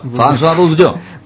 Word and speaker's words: François Bourgeon: François Bourgeon.